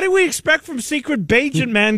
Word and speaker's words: Bagent: So the backup quarterback is Tyson, how do you say his name, Bagent? – Tyson Bagent. – Bagent.